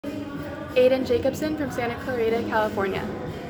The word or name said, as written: Ayden Jacobson